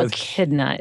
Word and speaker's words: echidna: Echidna. It's an echidna. – Echidna. – Which is like in the family of porcupine. It looks like a porcupine, but it's called an echidna. It's spelled E-C-H-I-D-N-A.